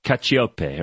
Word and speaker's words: Cacioppe: Cacioppe, right? – It is.